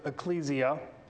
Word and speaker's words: ekklesia: Now, when we talk about the word, the word itself, church, it's actually a transliteration of a Greek word, ekklesia, or some people like to pronounce it ecclesia.